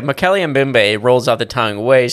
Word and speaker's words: Mokèlé-mbèmbé: And that's how they say it? – Yeah. Mokèlé-mbèmbé. – It sounds like the automated voice, you know, like a text reader. – Leave a message after the beep. Mokèlé-mbèmbé.